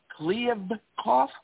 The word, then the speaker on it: Kliavkoff: The Pac-12 has hired a commissioner, George Kliavkoff. Did I pronounce that correctly? The head of the old MGM. – Yeah.